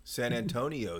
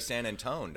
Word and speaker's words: San Antonio: San Antonio is what I said. – You said it right this time. – I did. – You usually say San Antone, don't you?